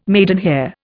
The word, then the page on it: maidenhair: Indian